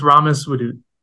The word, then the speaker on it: Ramis Wadood: I hope I said your name correctly. – Yes, it's it's Ramis Wadood.